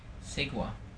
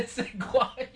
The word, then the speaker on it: segue: S-E-G-U-E. Pronunciation key, S-E, funny, curly bracket, G, segua. – Is it segua? – It's a segua, yeah.